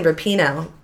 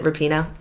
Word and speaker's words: Rapinoe: And if she's anything else, but that's why, the Megan Rapinoe, that's not how her name is pronounced, is it? Is it Rapinoe? Thank you.